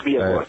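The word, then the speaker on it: Villas Boas: I mean, certainly if we go back to the infamous Villa Boas, I don't know if I'm pronouncing that right, but yes, Villa Boas, yes.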